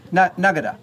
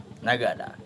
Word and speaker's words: Nagada: Nagada, with an inflection up. Or if you say Nagada, just level, it's peace. Nagada? Nagada. – Nagada is a response.